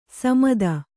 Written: ಸಮೆತ

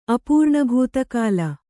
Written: ಅಪೂರ್ಣಭೂತಕಾಲ

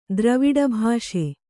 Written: ದ್ರವಿಡಭಾಷೆ

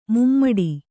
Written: ಮೂಮಡಿ

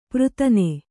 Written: ಪೃತನೆ

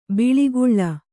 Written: ಬಳಿಗೊಳ್

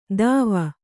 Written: ದಾವ

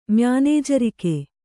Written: ಮ್ಯಾನೇಜರಿಕೆ

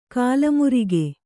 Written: ಕಾಲಮುರಿಗೆ

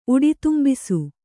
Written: ಉಡಿತುಂಬಿಸು

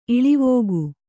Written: ಇಳಿವೋಗು

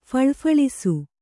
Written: ಫಳಫಳಿಸು

